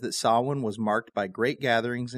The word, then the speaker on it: Samhain: You've probably heard it pronounced Samhain. A lot of people will say that, but the word is actually Samhain.